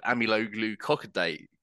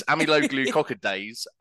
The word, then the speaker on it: amyloglucosidase: So we don't use champagne yeast, but we use an exogenous enzyme called amyloglucosidase. Well, I've butchered it already. – I blame this. – If you're listening or watching, don't drink this, because it does this to you. It's amyloglucosidase, and it's an exogenous enzyme, and that is my favourite phrase that I use when I speak about the beer.